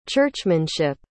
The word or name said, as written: churchmanship